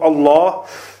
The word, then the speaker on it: Allah: When a letter with fatha or dhamma appears before the name of Allah, it will be pronounced with a broad sound or full mouth.